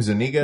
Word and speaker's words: Zuniga: He introduces Daphne Zuniga like is that how you say her name? Zuniga? – Yeah, Zuniga? I think so, yeah.